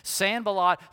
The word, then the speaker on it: Sanballat: You got to say it like that, like there's something caught in your throat, okay? Sanballat the Horonite and Tobiah the Ammonite.